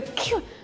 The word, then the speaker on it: queue: Oh, you know, I was just standing at the queue. Но ну, чтобы слишком часто вам не чихать, просто запомните одно единственное правило. Это слово произносится так, как и его первая буква.